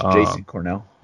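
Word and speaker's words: Jashon Cornell: So like a final, this is not really breaking news, but the Lions just signed a defensive tackle, Jashawn Cornell. I hope I said his name right. – I think it's Jason Cornell. What? – Jason. Okay, my bad.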